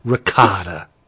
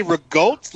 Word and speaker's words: ricotta: Ricotta. Christ. – What? You want me to say rigote? – Like ricotta. That's what it is. That's how you say it.